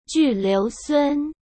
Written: Ju Liusun

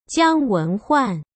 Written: Jiang Wenhuan

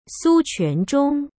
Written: Su Quanzhong

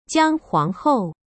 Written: Jiang Huanghou

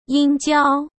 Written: yinjiao

